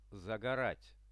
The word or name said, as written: zagorat